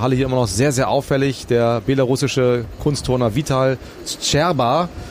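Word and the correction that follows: Vitaly Scherbo: Wir haben neulich noch drüber gesprochen, Holger. Ich weiß nicht, du kennst es mit der Aussprache ein bisschen besser. – Vitali Tscherbo. – Tscherbo, siehst du.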